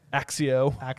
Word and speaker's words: Accio: I mean, Osseo, Akio, Accio.